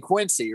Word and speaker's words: Quincy: That's how they say it, though. They fucking actually say Quincy. Like, there's a Z in it. – Fucking Quincy, dude.